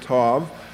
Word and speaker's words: tau: The Hebrew word used here is tau, or some of you pronounce it tav, which means mark and also signifies the specific mark made for the last letter of the Hebrew alphabet.